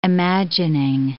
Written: imagining

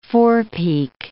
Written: forepeak

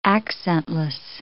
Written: accentless

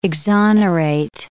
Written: exonerate

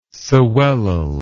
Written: sewellel

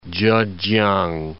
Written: Chekiang

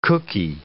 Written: cookie

